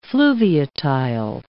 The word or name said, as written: fluviatile